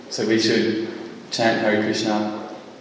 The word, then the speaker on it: Krishna: particularly with the short A on the end of Krishna, not the long A, which is a long A with Krishna is indicating Draupadi.